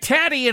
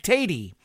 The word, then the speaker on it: Tatty: Jack Tatty and his swinging laddies. I used to say Jack Tatey, but then it doesn't rhyme with ladies, because they're not ladies, they're laddies.